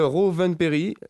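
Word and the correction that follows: Rovanperä: pardon pour la prononciation. – Rowan Perra.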